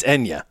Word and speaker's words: Enya: That wasn't Anya? – No. I don't even know that's how you say it. Is it Enya? It's Enya. Isn't it? – If you say so.